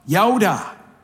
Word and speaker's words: yada: And the word here that's rendered to know in the NASB is derived from the Hebrew term yada, is the way it's pronounced.